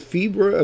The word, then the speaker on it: Fibre: Is fiber, uh, fiber, fiber. Excuse me, I've been corrected. That's uh, spelled F-I-B-R-E, which is the UK version of fiber. Pronounced Fiber, Fiber Coin is now part of the SuperNet newest core coin.